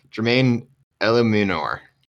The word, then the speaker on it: Jermaine Eluemunor: Russell Bodine, Corey Cunningham, and I'm going to butcher his name here, Jermaine Eluemunor.